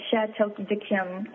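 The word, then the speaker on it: Château d'Yquem: would be a Chateau de Kim, and that's, I'll spell it for you. It's C-H-A-T-E-A-U and D apostrophe, excuse me, Y-Q-U-E-M.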